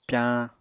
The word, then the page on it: pyañ: Apatani